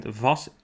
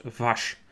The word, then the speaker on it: Vas: and he said that we were actually mispronouncing Kata Blanka Vas. It isn't Vas, we should pronounce Vash.